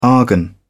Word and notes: argon: British English

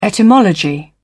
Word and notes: etymology: British English

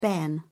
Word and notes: bairn: British English

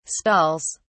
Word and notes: stalls: British English

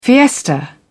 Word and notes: fiesta: British English

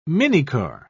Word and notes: minicar: British English

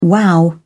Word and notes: wow: British English

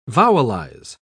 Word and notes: vowelize: British English

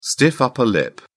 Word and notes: stiff upper lip: British English

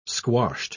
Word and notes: squashed: British English

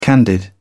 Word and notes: candid: British English

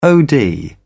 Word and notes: od: British English

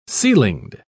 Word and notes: ceilinged: British English

